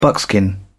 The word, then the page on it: buckskin: British English